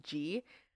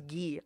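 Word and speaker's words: Gee: For a long time I didn't know it was pronounced G. Even though they say in the song, I thought it was ghee. I just kept pronouncing it ghee. – Nice. – Like the butter.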